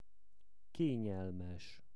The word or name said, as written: kenyelmes